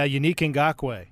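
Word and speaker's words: Yannick Ngakoue: mixing that with the acquisition of I can't I can't say his name is a young Yannick. – Yeah, Unique Ngakwe. Yeah.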